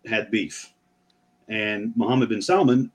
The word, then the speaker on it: Salman: So anyways, Prince Bin Talal and Mohammed bin Salman, which I also got to say, that's the only time it's okay to pronounce the L in Salman.